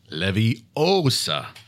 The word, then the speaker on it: Leviosa: All Harry Potter fans know this charm needs to be pronounced Leviosa, but